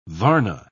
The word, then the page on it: varna: British English